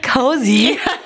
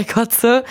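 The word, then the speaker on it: Koze: Genau, DJ Cozy. ja, wird doch auch dauernd falsch ausgesprochen. Aber ich habe mittlerweile gehört, dass man beides sagen kann. Ach so, nee, für mich ist und bleibt DJ Kotze.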